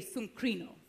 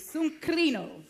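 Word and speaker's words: sunkrino (συγκρίνω): Everybody say sunkrino. Say it like you're Greek. Sunkrino. That's better. There we go.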